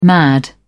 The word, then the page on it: mad: British English